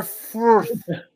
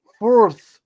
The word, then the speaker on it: Fürth: Greuther Fürth. – What? – Fürth. It's the U with the two dots on top.